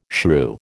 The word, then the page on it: shrew: American English